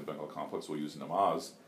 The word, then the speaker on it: namaz: Yeah, namaj. – Namaj, okay, yeah, that's the most Bengali pronunciation I've heard, yeah.